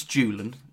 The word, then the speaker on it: Julen: I know it's Julen, so it's okay. It is a hard J.